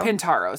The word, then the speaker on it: Pintauro: Pintaro is how you say his last name.